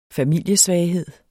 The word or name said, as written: familiesvaghed